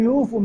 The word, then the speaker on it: يوفوا: لم يوفون، واو الجماعة وراها ألف الفارقة، لم يوفوا ملاء، واو الجماعة بعدها ألف الفارقة، تكتب ملاء إملاء، تكتب ولا تلفظ.